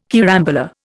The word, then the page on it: carambola: Indian